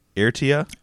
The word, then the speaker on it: Eritrea: I'll spell it as well. Iridia, E-R-I-T-R-E-A. Eritrea? – Eritrea.